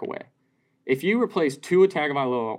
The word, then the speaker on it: Tagovailoa: Pronounce Tua's last name. – Tagovailoa. – Yeah, there it is.